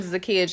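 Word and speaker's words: Zachia: I am Zakiya Jones. – I am Zakiya Jones.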